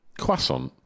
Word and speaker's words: croissant: But at number five, the croissant. – Ooh. – Am I saying it right? – Croissant.